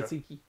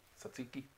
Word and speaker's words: tzatziki: Tzatziki. – Tzatziki.